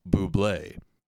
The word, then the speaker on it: Bublé: Said, I remember around the time of his first album, there was an especially obnoxious ad campaign with the tagline, "You pronounce it Bublé," to which any man with pride has to reply, "Fuck you, Bubble, you're not the boss of me."